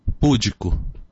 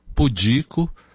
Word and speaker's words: pudico: Nobel, rúbrica, austero, púdico, gratuito, recorde, ínterim, catéter, mister. Percebeu alguma coisa errada? A pronúncia de todas essas palavras está errada. Há erro de prosódia, e erro de prosódia é chamado de silabada. Como seria então a correta pronúncia, a correta acentuação dessas mesmas palavras? Seria assim: Nobel, rubrica, austero, pudico, gratuito, recorde, interim, cateter, mister.